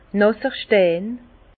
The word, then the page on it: nàsser Stein: Alsatian; Bas-Rhin; Herrlisheim